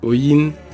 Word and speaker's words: Oyin: Sean Oin, designer et fondateur de Oin Studio. – Oin, c'est comme ça qu'on prononce mon nom de famille euh, dans mon dialecte maternel. C'est Oin, c'est pas Oini.